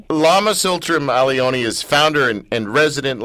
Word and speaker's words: Tsultrim Allione: Well, well speaking of names that I've been struggling to pronounce, uh, Lama Tsultrim Allione.